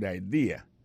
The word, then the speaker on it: idea: T-H-A-T, apostrophe S, good, G-O-O-D pour good, ça veut dire bon, idea, c'est I-D-E-A, ça veut dire idée, that's a good idea. Alors, accent vient tomber dans la deuxième syllabe là, one idea. Alors, moi, c'est idée, vous faites trois syllabes vraiment, I-D-A.